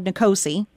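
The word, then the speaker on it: NCOSE: which in short is called NCOSI, right? That's how you pronounce right.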